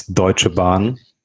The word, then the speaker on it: Deutsche Bahn: The first company you mentioned is Deutsche Bahn, but I think you pronounced it very, very good.